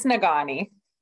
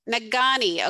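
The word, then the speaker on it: Negaunee: It's Naganai. – Naganai.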